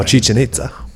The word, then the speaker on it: Chichen Itza: Oh, Chichen Itza.